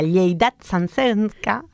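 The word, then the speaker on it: Lleialtat Santsenca: Jornadas que arrancan esta semana y se van a desarrollar en el barrio ahí en Metro Plaza de Sants, concretamente en la Lleidat Sansenca. – Así se Lleidat Sansenca. – Gracias por pronunciar bien el catalán.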